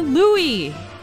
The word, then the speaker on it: Louis: Yes, they made it pretty clear in their press releases that it was Louis and not Louis. It's the French pronunciation.